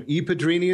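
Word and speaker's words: I Padrini: I must say it wrong every time. Ipadrini is the name of it? – Correct. Ipadrini di Antonello is the Godfathers of Antonello's.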